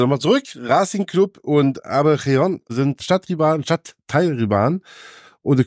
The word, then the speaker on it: Avellaneda: Und Independiente und Racing Club Buenos Aires sind im selben Stadtteil in Buenos Aires, in Avellaneda. Ich hoffe, ich habe es richtig ausgesprochen. – Sí, absolutamente.